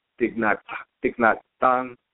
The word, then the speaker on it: Thich Nhat Hanh: The Thich Nhat Hanh. That's how you pronounce it. I believe that's correct.